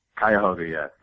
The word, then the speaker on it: Cuyahoga: Did I say that correctly, Cuyahoga? – Cuyahoga. There's a bit of a tongue twister.